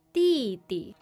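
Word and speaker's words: dìdi: Di-di. – Younger brother. – Di-di. – That is a fourth tone and a neutral tone.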